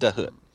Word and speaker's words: Dahut: I think it is just pronounced Dahut.